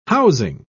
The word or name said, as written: housing